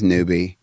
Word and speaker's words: Nuby: It's like, okay, my last name is Newbie, and it's spelled N-U-B-Y. But if you spell it N-U-B-E, that's the Spanish word for cloud. And I was like, while my last name doesn't directly mean cloud, it, you know, phonetically, it's okay.